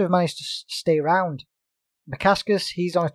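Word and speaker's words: Bukauskas: Modesta Bukaska. – Bukaskus, I'm pronouncing it as. – Modescus Bukaskus. We'll go with that.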